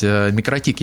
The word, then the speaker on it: Микротик: Оно переводится как «маленькая сеть», «микро-маленькая», «тикл-сети», поэтому, в принципе, по-латвийски По-латышски всем ударением получается микротик, ну, то есть не так, как вы все время ставите удаление на О, но так, как я сказал.